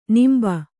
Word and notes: ನಂಬಿ: Kannada

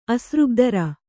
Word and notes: ಅಸೃಗ್ಧರೆ: kannada

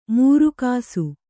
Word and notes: ಮೂರುಕಾಸು: mūru kāsu

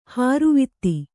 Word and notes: ಹಾರುವಿತ್ತಿ: hāruvitti

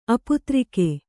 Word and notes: ಅಪುತ್ರಿಕ: aputrika

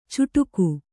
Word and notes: ಚುಟುಕು: cuṭuku

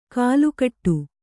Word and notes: ಕಲುಕಟ್ಟು: kalukaṭṭu

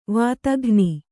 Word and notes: ವಾತಘ್ನಿ: vātaghni

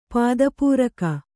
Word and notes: ಪದಪೂರಕ: pada pūraka

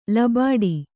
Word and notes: ಲಬಾಡಿ: Kannada

